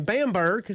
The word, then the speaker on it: Bamberg: And that's in Bamberg. Is that the correct way to pronounce that? – Yep.